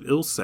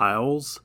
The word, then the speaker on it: Ilse: I didn't realize it was pronounced Ilse. I've always called it like Isles.